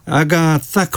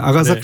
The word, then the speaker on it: 아가사: 아가사 크리스티 발음을 좀 신경 써서 해봤습니다. 아가사 네.